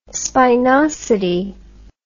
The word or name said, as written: spinosity